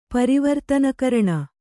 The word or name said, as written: ಪರಿವರ್ತನಕರಣ